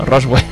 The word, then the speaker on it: Roswell: Es que es lo típico que dices, esto es una palabra en inglés, seguro no dice Roswell, se dice Roosevelt, seguro. Pero no, mira, se dice como se lee, ¿no? Roswell.